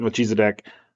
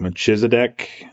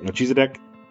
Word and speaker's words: Melchizedek: And I say it Melchizedek. There are a lot of other pronunciations, like Melchizedek. There's just a lot of different ones. I just like Melchizedek.